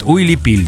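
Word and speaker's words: уипиль: Уилипиль, да. – Да, вот это вот. – Да. – Уилипилю хранят, да? – Уилипиль. Правильно говорить, вот Уилипиль, да.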